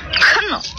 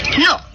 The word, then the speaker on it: knock: Cannock. – Nah, the K is silent. – Knock, and the door shall be opened unto you.